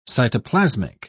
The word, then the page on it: cytoplasmic: English; British English